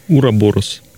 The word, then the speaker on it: Ураборос: Как правильно произносится название? – Ураборос. Ураборос. Да.